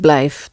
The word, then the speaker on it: blijf: Ik blijf, that's right, with an F at the end.